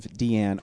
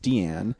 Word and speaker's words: Diane: The first one I watched was Fur, an imaginary tale of Deanne Arbus. Her name is spelled Diane, but it's pronounced Deanne.